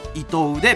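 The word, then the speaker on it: 依藤: 読み方は伊藤でベタだけど漢字は依存の「い」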